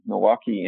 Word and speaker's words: Milwaukee: Milwaukee and there's a syllable missing in that if you say it correctly.